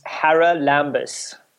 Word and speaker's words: Haralambous: It's easier than it looks. It's Hara Lambus.